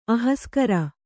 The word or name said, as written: ಅಹಸ್ಕರ